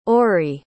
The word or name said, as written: ori